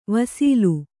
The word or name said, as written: ವಸಲೆ